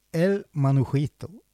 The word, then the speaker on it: El Manujito: Og nå skal jeg prøve å uttale det her riktig, for det er jo spansk. Og den låten vi skal høre nå heter El Manujito. – Ja. – Er det close enough? – Close enough.